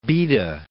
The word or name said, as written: Baeda